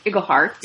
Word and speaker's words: Iglehart: And Aladdin, James Monroe Iglehart. – Yes. – Is that how you say it? Yeah.